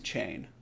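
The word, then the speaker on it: Cheyne: My last name is Chain. It's spelled really weird. It's Scottish. It's Gaelic. It's C-H-E-Y-N-E. – Okay. – So, but phonetically, it's actually how you... pronounce chain. If you look in the dictionary, C-H-A-I-N. – Uh-huh. – If phonetics are C-H-E-Y-N, which is very, very, very close to my name.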